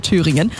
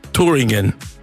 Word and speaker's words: Thüringen: Ray Dalton, mega Stimme, vor 2 Jahren auch live bei uns auf der Bühne bei Das Konzert von Antenne Thüringen. Und ein bisschen Deutsch hat er dafür auch gelernt. Wo gibt's die besten Hörer, lieber Ray? – Thüringen. Thüringen.